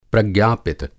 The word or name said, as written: प्रज्ञापित